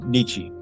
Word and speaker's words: Nietzsche: When he was talking about the book depository, he said, like Nietzsche, is that how you pronounce it? – Friedrich Nietzsche, yes.